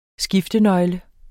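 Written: SKIFTENØGLE

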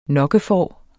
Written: nokkefår

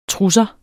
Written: trusser

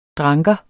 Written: dranker

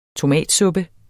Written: tomatsuppe

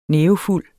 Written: nævefuld

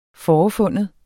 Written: FOREFIND

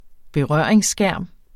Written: berøringsskærm

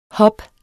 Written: hub

